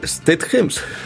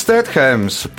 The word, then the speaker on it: Stedhems: Tagad pareizi uzvārdu izrunāt Stedhems. Džesons Stedhems.